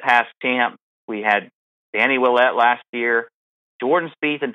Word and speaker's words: Willett: Willett.